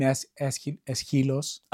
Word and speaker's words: Aeschylus: How should it be? – We call him Aeschylus.